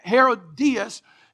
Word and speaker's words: Herodias: And as a result of that, Herodias, I believe is how you pronounce her name. Herodias is how you pronounce her name.